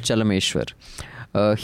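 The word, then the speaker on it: Chelameswar: Is it Chalmeshwar? – Chalmeshwar.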